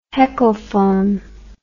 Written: heckelphone

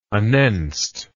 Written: anenst

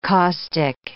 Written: caustic

